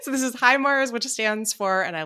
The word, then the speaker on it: HIMARS: Is it HIMARS? – HIMARS. – I'm not going to do the Tommy Wiseau impression. – HIMARS.